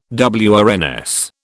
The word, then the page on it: WRNS: British English